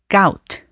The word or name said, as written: gout